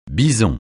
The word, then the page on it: bison: francais